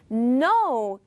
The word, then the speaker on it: know: No, K-N-O-W means 알다. 여기서 K는 발음하지 않고요.